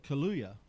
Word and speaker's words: Kaluuya: And Daniel Kaluuya, is that how you say it? – Yes.